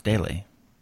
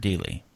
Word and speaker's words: Dealey: I've never heard it pronounced daily. I've always heard Dealey.